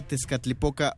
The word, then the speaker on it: Tezcatlipoca: sí, Ellos vienen a hablarnos acerca de la obra Tezcatlipoca Online. ¿Lo dije bien? Sí, sí, sí. Pronuncié bien el náhuatl y el inglés después.